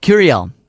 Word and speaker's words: Gurriel: The Houston Astros first baseman, Yuli Gurriel. I don't know if I'm saying that right. – I think you are. – It's not with an H sound. Gurriel.